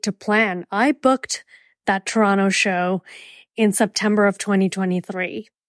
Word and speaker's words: Toronto: And that is how you say it, Toronto, like T-R-A-A-A-A-N-T-O, Toronto.